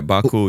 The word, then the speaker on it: Baku: It's called Baku. Did I pronounce that right? – That's right. Yeah, Baku. Yeah.